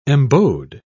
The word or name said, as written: embowed